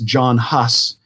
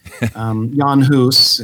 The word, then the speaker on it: Jan Hus: In English, we often hear that pronounced as John Huss. Jan Hus is better.